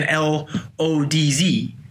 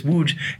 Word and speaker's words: Łódź: You and I know what Łódź is, but if you look at the spelling, to a Westerner, it looks like an L O D Z, Yes. – Something like that. I pronounce it Lodz or something like that. It's not even close, mm-hmm. right? – Yeah. – So in Polish, it's Łódź.